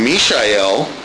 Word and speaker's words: Mishael: The Hebrew name Mishael, which is actually the way it is pronounced, means who is what God is, or who is like God.